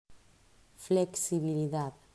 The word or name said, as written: Flexibilidad